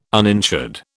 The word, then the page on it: uninsured: British English